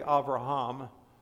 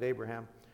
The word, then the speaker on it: Abraham: Now the Lord said to Abram, this is before his name was changed to Abraham, literally Avraham, but we pronounce it Abraham.